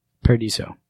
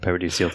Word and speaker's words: Paradiso: Paradiso. – Although I keep pronouncing it Paradiso, that's probably my fault.